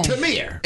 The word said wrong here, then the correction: Tamar: It is Tamar. – It ain't Tamir. – I know it ain't. It's Tamar. But in Russian, it's pronounced Tamir.